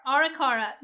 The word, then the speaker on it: Arikara: Arikara.